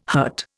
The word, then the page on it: hurt: Indian